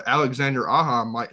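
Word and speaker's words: Alexandre Aja: You know, because we talked about it on our podcast, and I know, you know, Alexander Aja, is that how you say his name? – Yeah.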